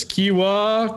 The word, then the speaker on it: Kiawah: Kiawah.